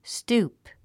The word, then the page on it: stoop: stúːp; ストゥープ